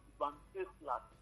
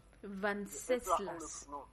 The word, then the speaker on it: Venceslas: Oui, oui, je veux juste vous aider par le prénom là. On prononce Venceslas. – Venceslas. – Venceslas. – Voilà, c'est comme ça qu'on prononce.